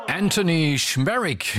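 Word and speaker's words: Anthony Szmierek: Anthony Schmerich, genau so wird er ausgesprochen, ja, Anthony Schmerich mit Seminole aus seiner wunderbaren neuen Platte, die wir ausführlich bereits vorgestellt haben, hier auf Radio 1 Musik aus Manchester.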